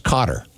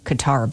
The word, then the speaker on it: Qatar: I have a friend that lived there for a few years, and he said it's pronounced Cotter. – You told me it was Cotter, so yeah. I like Qatar better.